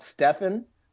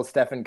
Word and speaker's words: Stephen: I just like to say that that I used to be offended by the name, by being called Stephen, until Stephen Curry came along. Now I love being called mispronounced and being called Stephen. So if you want to call me Stephen, that's fine as well.